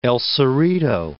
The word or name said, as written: El Cerrito